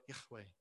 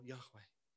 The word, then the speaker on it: Yahweh: And we think after many years of scholarship that the best way to pronounce it is if I was a Hebrew speaker, I could do it uh, even better, but Yahweh or something like that.